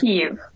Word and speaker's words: Kiev: Did I say that correctly? Kiev? – Kiev. – Kiev. From Kiev.